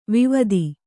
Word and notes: ವೈವಾದ: vaivāda

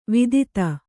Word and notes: ವಿದಿತೆ: vidite; Kannada

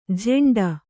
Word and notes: ಝೆಂಡ: jheṇḍa

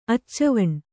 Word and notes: ಅಚ್ಚವಣ್ಣ: kannada

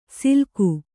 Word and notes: ಸಿಲ್ಕು: kannada

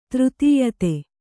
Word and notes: ಧೃತಿಯುತ: dhřti yuta

